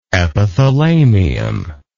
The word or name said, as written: epithalamial